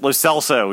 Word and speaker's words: Lo Celso: We've been saying it wrong. I blame Michael Caley. Mostly because he's not here. But yes, Lo Celso. We need to get it right now.